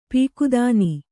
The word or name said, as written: ಪೀಕದಾನಿ